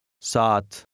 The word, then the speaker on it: saat: Number 8 has two A's side by side. They are pronounced with a very slight break between them. Listen and repeat. – Saat.